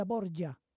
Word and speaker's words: Borgia: Pero claro, al pasarlo en italiano ellos dicen Lucrecia Borgia, para poder pronunciar esa J así de esa manera.